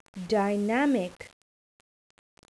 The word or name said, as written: dynamic